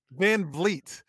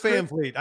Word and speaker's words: Van Vliet: It's Van Vliet. – Van Vliet. – Chris Van Vliet. I don't know why my ancestors spell it the way they did, but that I should be an E. V-L-E-E-T would make so much more sense.